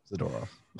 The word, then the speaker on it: Zadorov: I can never say his name right, so I'm going to try it. Zadorov. Yes, Zadorov. Way to go.